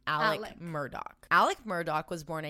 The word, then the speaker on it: Alex: But his son, Richard Alexander Murdaugh, who goes by Alec, even though it's spelled Alex, he goes by Alec.